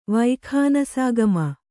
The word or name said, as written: ವೈಖಾನಸಾಗಮ